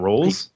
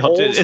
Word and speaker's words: Rolls: Is it not Rolls? – R is never pronounced, but the R always has an H. – Right. So that's what, is it Holes? – I think it's Holes.